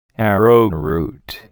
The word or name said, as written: arrowroot